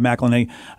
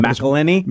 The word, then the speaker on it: McElhenney: Yeah, yeah, which of course the Always Sunny guy, Rob McElhenney, Mac from, I always say his name wrong. – McElhenney? – McElhenney, right.